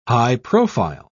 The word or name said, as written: high-profile